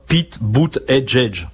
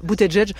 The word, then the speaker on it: Buttigieg: Pete Buttigieg. – Voilà, dit comme ça, c'est facile. Buttigieg. Le truc, c'est que ça s'écrit pas du tout comme ça. – Évidemment. Mais en tout cas, Pete Buttigieg, hier à South Bend, Indiana, a officiellement annoncé sa candidature à la Maison Blanche.